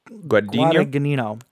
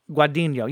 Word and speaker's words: Guadagnino: So it's being helmed by Universal is redoing it, and it's being helmed directed by Luca, I'm going to say his name wrong, Luca Guadagnino. – Guadagnino. – I've always said Guadagnino, I think. – Guadagnino, yeah. So he's the guy that did the new Suspiria.